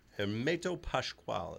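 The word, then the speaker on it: Hermeto Pascoal: Hermeto Pascual, as Leon gave that little shh sound on there.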